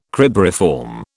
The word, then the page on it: cribriform: British English